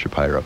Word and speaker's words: Shapiro: It's spelled Shapiro, but I think it's pronounced Shapiro.